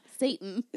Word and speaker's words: seitan: So, seitan, how you pronounce it. That's what I try. – Yeah. Satan.